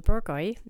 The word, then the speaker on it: Bourgogne: That's your Bourgogne, and that's how you say it. It looks like Bourguignole.